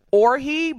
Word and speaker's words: Orji: I think it's pronounced Orhi, but it's spelled like orgy.